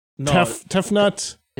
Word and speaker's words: Tefnut: S-H-U. – It sounded like you said Tiefnut. – Tefnut. – Yeah. – T-E-F-N-U-T.